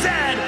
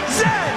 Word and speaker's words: Zed: Chesterfield is a coach, and it is pronounced Zed, not Z, Zed.